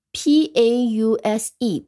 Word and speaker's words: pause: 那么它的读音和拼写也很简单，后面有一个不发音的 e，前面 a u 发成 o，pause，这 p a u s e pause。